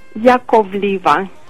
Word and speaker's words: Yakovleva: Yakovleva.